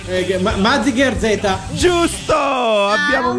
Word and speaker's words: Mazinger Z: Mazinger Z.